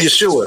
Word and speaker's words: Yeshua: And Yeshua. – It's pronounced like Joshua.